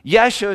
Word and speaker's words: Yeshua: Yeshua is how you'd say it in our language. Jesus is how you say it in English.